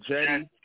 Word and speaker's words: Jeide: J-E-I-D-E. It's pronounced as Jetty. It's like Jetty. I mean, it's like Jedi Jetty with the E, or it's like Eddie with a J.